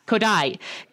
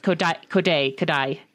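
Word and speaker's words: Kodaly: Kodai. It's like spelled Kodali, but it's pronounced Kodai.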